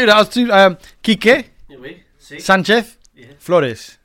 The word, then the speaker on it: Quique Sanchez Flores: 8 games into the season, 8 games under the stewardship of one Quique Sanchez Flores. Did I say that right, with your Spanish expertise? – You did it right. – Here, you do it, quickly. – Quique Sanchez Flores.